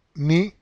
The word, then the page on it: ni: Spanish